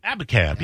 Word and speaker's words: Abacab: Abacab.